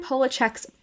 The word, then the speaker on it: Polachek: So if you're into that scene, Caroline Polachek's Ping is amazing. Her name is spelled Caroline, like Caroline, and then last name Polachek, P-O-L-A-C-H-E-C-K. So, like, polar without the R. And then check, like, check it out.